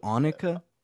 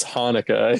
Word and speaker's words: Hanukkah: Is that how you pronounce it in Hebrew? Annika? – No, it's Hanukkah.